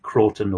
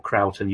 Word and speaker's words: Croughton: RAF Croughton or Croughton, you hear both pronunciations,